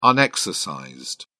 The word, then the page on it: unexercised: British English